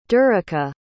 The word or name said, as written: dereka